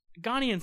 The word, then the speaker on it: Ghanaian: I think that's how you say it, Ghanaian? – G- Ghanesian. Ghanaian. – Ghanaian sounds correct.